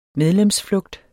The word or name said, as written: medlemsflugt